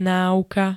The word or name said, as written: náuka